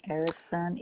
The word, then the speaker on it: Erickson: Marjorie Velez, V-E-L-E-Z, Erickson, E-R-I-C-K-S-O-N.